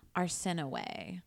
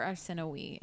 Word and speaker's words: Arsinoe: She had, I think it was 5 siblings, 3 girls, 2 boys, one of which is a girl, her sister named Arsinoe or Arsinoe. It's pronounced both ways.